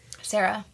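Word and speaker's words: Cera: Sarah.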